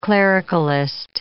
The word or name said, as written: clericalist